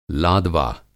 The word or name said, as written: लादवा